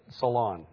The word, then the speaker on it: salon: I said salon, not a long O, not saloon.